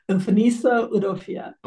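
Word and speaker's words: Mfoniso Udofia: And how do you pronounce the playwright's name? I want to make sure I say it right when I do. – Umfaniso Udofia.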